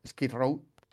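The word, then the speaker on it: Skid Row: guitarrista y con influencias como Sky Road, eh, Guns N' Roses. Bueno, Sky creo que lo pronuncia fatal, Sky Row eh, Guns N' Roses.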